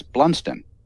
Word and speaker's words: Blunstone: and the way the BBC interviewer pronounced his last name was Blunston.